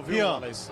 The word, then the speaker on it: Rayan: Saindo camisa 23, Igor Fernandes, entrando 6, Rayan, fazendo a estreia. – É Rian, viu, Rian. Rian mesmo. Ok. É porque é com Y, né? Eu aprendi que era diferente as pronúncias, né?